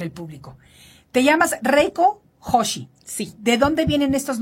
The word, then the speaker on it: Joshi: Hoshi cómo se escribe? Eh, con J es J O S H I Cosmetics.